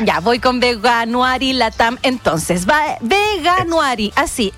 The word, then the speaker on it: Veganuary: ¿Cómo se dice, Mauricio, por favor? Sálvame. – Mira, la verdad... ¿Cómo se? Veganuary, Veganuary.